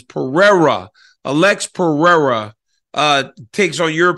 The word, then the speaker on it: Pereira: Alex Pereira, I keep saying that wrong. Like, how long will it take me to recognize that it's Pereira and not Pereira? Because a lot of Brazilians call the R, the H, and the whole confusing thing.